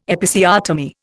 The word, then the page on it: Episiotomy: Indian